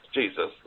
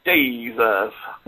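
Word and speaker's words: Jesús: Jesus. – Thank you, Jesus. It has three syllables. – Jesus.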